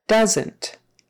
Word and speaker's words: doesn't: Doesn't, doesn't, another contraction that does have two syllables. It's short for does not. Doesn't, doesn't, and that second syllable is just more like an NT squished together. Doesn't. And the OE is pronounced like a short U. Duh, uh, uh, doesn't.